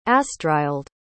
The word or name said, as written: astrild